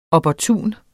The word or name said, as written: opportun